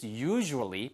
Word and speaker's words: usually: It's usually. 발음 주의하세요. 유절리가 아니고요. Usually. Usually. 이렇게 들립니다.